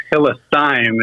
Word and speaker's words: Hillesheim: It's actually one of those tricky German names. It's Hillesheim is how you pronounce it.